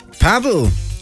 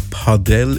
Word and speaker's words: Padel: Paddel, oder richtig ausgesprochen, Padel ist aktuell voll im Trend.